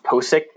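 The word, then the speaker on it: Pocic: Or they picked, you know, the LSU lineman who his name is pronounced Posick, apparently.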